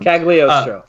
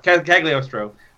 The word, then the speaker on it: Cagliostro: And also, though I've seen a lot of the second series from the 70s, and I've seen Castle of, I can never pronounce the second term. – Cagliostro. – Cagliostro.